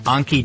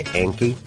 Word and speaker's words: Anki: Now, a lot of Americans might say Anki. – Anki, yeah. – Like it rhymes with Yankee. – Yeah, I guess so. It's like on key or like almost like you're saying donkey. – Yeah, it's spelled A-N-K-I.